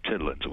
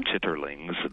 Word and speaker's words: chitterlings: It comes from the South originally, but it's to cook chitlins. We do it on Thanksgiving and Christmas. – Mm-hmm. – Chitterlings, the proper pronunciation, but chitlins for short,